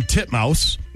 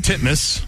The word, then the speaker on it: Titmus: Pronounce it the way it's spelled, Jason. – Ariane Titmouse. Titmus. – There you go.